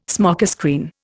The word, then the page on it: smokescreen: Indian